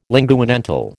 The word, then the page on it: linguodental: American English